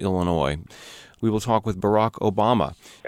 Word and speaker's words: Barack Obama: Barack Obama. And people mispronounce it and call me Alabama.